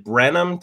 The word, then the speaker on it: Brenham: They're from is it Brenham, Texas? Did I say that right? – Right. – That's Brenham, right.